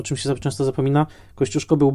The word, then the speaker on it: Kościuszko: No właśnie, jeżeli jacyś producenci nas słuchają, to pamiętajmy, że Kościuszko, co prawda wymieniane, wymawiane jako Kotausko,